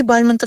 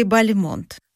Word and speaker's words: Бальмонт: Правильное ударение Бальмонт. Но хотя и Бальмонт, и Бальмонт, возможно. Почему? Да все очень просто. Когда Бальмонт уехал во Францию, решил называть себя на французский манер.